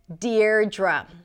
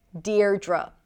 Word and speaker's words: Deirdre: The spelling can be a little confusing here, and this is how you pronounce it. You start with the syllable deer, deer, like my dear, and then the second syllable is dr, dr, Deirdre, Deirdre.